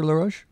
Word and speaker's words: Laroche: Today joined by Dr. Daniel Laroche. Am I pronouncing your name properly, Dr. Laroche? – Yes, that's fine.